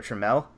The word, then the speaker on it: Trammell: And they dealt him and two other prospects in order to get one prospect back, Taylor Trammell, I believe is how you say his name.